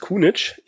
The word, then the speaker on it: Kuhnitzsch: Und zwar ist unser Gast heute der Clemens Kunitsch. Ich hoffe, ich habe das richtig ausgesprochen. – Na? Ne? Vollkommen richtig, ja.